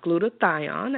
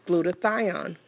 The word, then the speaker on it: glutathione: It's pronounced glutathione again, and guys, I'm going to spell it for you. It's spelled G L like Larry, U T like Tom, A T like Tom, H like Henry, I O N like Nancy, E like Echo. Again, it's glutathione.